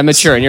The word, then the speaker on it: Maturin: Literally, the backstory is this turtle, Maruten or something. – Maturin? That's how it's spelled. – It happens in the book. – Oh, yeah. – Maturin? – It's spelled Maturin. – Yeah, Maturin. You're right.